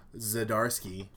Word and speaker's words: Zdarsky: It's by Matt Fraction and Chuck Zdarsky, which I found out that's how you say it.